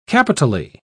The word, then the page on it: capitally: British English